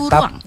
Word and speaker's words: kuruang: Bajunya sebenarnya kan baju kurung ya, tapi di Sumatera Barat kita memanggilnya baju kuruang. Jadi ditambah A dia, baju kuruang basiba.